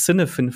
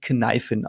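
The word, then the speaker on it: Cynefin: Ähm, ich sage es erstmal falsch, nämlich damit jeder weiß, worum es geht, nämlich das Cinefin Framework, das, wenn ich es gerade richtig im Kopf habe, eigentlich Kneifin ausgesprochen wird.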